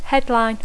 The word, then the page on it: headline: English